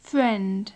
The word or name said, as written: friend